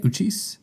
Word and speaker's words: Uchis: Uchis? Is that how you say it? Uchis? – Yeah.